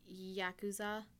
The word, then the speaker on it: Yakuza: Right, because the Yakuza, mm-hmm.